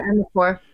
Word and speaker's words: Amirpour: Amirpour.